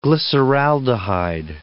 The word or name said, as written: glyceraldehyde